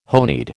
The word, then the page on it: honied: American English